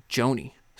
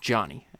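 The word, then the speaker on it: Jony: Even though I keep wanting to say Joni. So I don't know if I've made that mistake, but his name is it's pronounced Johnny. If you hear me mispronounce it Joni, it's just because I can't pronounce any word correctly.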